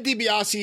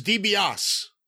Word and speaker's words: DiBiase: And then Watts, at random times, will just call him, and here's DiBiase. He'll just leave off the E. He'll just go, Ted DiBiase in the ring.